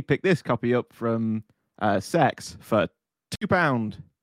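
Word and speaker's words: CeX: I don't think that it's pronounced sex. I think it's C-E-X. – No, it's they pronounce it sex on the adverts. – True story. – It's definitely called sex.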